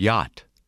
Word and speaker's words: yacht: The letter combination CH is silent in the word yacht. Listen and repeat.